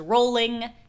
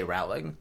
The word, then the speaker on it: Rowling: To me, it looks like it rhymes with prowling, but I'm clearly wrong because a person knows how to pronounce their own goddamn name.